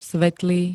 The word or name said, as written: svetlý